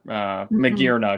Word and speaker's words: Magearna: Yeah, Majarna is an artificial Pokemon. That's a really good point. Or Magearna, excuse me.